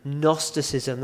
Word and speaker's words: Gnosticism: It begins with a G. It's one of those really annoying words that begins with a G, but you don't pronounce it. So it's not Gnosticism, it's Gnosticism.